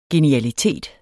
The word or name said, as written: genialitet